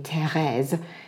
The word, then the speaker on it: Therese: he used to pronounce my name, Therese, in a very French way.